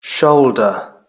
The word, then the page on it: shoulder: British English